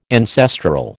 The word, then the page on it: ancestral: American English